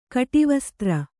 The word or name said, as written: ಕಟಿವಸ್ತ್ರ